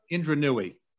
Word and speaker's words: Indra Nooyi: Indra Nooyi.